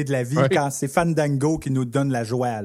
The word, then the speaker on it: Fandango: Il faut aspirer les A comme Fandango.